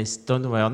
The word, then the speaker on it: Stonewall: Quero saber o que são os 50 anos de Stonewall. Falei então. certo? Falei certo? – Falou, falou. É uma palavra em inglês, né? É Stonewall.